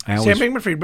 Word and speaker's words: Bankman-Fried: Sam Bankman-Fried, but read Ro's line here.